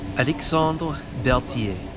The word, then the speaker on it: Alexandre Berthier: But anyway, since you did manage to get a hold of me, you pronounced it as such. Alexandre Berthier.